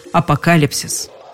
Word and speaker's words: апокалипсис: Ну а в существительном все-таки без вариантов с ударением «апокалипсис».